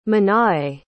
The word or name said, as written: Manae